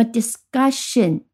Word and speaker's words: discussion: 那么 discussion 就是把 ss 后面加上了 ion，变成 discussion。好，这里看上去是 ion，但实际上它的发音不发音不发成 in，它发成 shin，和 tion 前面发的是一样的。